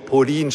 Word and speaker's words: Pauline: Pauline Marie Charicot, Pauline geschrieben. Einige haben geglaubt, ich spreche von einer Polin. Ja? Ja, also das ist nicht geografisch Polen, sondern Pauline. Pauline spricht man es. Die Franzosen sind komisch. Die schreiben was und sprechen es ganz anders aus.